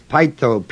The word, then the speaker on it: peitho: The root word is pytho, P-E-I-T-H-O, long O.